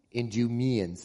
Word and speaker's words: Idumeans: These became known as the Idumeans, if I'm saying that right. Pardon? Idumeans, thank you.